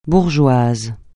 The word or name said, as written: bourgeoise